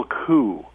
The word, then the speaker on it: coup: And it's pronounced Intertribal Coup, C-O-U, as the French would pronounce it.